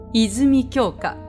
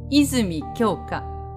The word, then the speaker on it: 泉鏡花: 泉鏡花。泉鏡花。これもね、ひとまとまりアクセントをよく聞くんですよね。